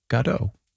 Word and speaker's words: Godot: But we did read that Beckett himself said Waiting for Godot.